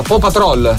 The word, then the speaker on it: Paw Patrol: Questo non so neanche di che ho portato mia figlia al cinema a vedere Paw Patrol. Come si pronuncia? Paw Patrol. – Si dice Paw Patrol.